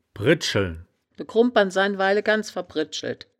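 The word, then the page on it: bridscheln: Wittlicher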